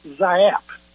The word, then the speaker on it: Giap: It's spelled G-I-A-P. But it's pronounced Zap.